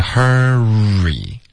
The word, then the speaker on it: hurry: 这个 r 的这个音别发成 hurry，这个 hurry 靠舌头的动作而已呢就弱化了，然后很容易就导致误解，是 hurry，这个 re 开头嘴唇要撅起来，而且也不要读成哼哼老师的名字了。